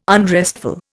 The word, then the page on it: unrestful: Indian